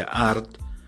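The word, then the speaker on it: arth: Y como oso en gaélico se pronuncia art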